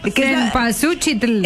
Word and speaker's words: Cempasúchitl: Por ahí vas, pero no se dice así, flaca. – Si te la chuc. – Selpazuchitl. – Cempasúchitl.